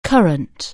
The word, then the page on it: current: anglais